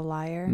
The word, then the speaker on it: lyre: The lyre. – Mm-hmm. – Yeah. That is how you say that.